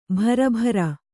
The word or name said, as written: ಭರಭರ